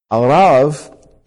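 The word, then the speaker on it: Arav (ערב): Arav is the Hebrew pronunciation, but you and I would pronounce this word Arab.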